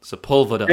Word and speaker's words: Sepulveda: Sepulveda.